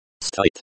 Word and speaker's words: Стоит: Стоит. Стоит, pronounced like стоит, with the O sound as in go, and the I sound as in machine.